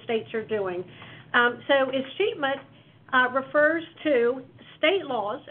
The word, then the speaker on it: escheatment: but it's escheatment.